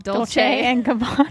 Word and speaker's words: Dolce & Gabbana: Dolce & Gabbana.